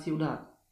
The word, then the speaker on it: ciudad: Głoska d. W środku wyrazu z reguły wymawiana jest słabiej, natomiast na końcu jest prawie niesłyszalna. Na przykład un padre, la pared, la ciudad.